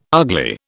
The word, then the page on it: ugly: British English